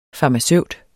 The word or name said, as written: FARMACEUTEN